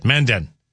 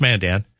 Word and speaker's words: Mandan: And another one oftentimes, and it's really more the emphasis of where they put, like Mandan, they'll say Mandan. Some people will say that, Mandan. But it's Mandan.